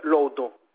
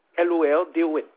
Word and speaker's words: Lourdes: De Lordon, attendez, j'ai le temps que j'écris tout ça. En route. – De Lourdes, Lourdes, comme ça se prononce, Lourdes.